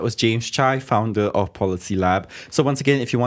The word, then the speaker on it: PolisiLab: It's Policy Lab, but that's spelled PolisiLab.